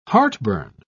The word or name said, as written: heartburn